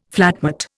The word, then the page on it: flatmate: Indian